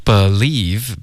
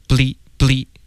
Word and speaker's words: believe: Okay, believe, 别发成 bleep. Okay, 这是一个音节，我们要 believe 两个音节。